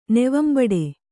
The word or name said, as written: ನೆವಂಬಡೆ